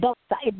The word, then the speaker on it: Doxa: So, you know, with this book, you're talking about the DOWOX. Am I saying that right? DOWOX method? – DOXA. No, it's DOXA. D-O-X-A. D-O-X-A.